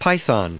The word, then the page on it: python: py*thon; American English